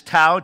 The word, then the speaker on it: tau: which is tau, T-A-U, is how we phonetically say it.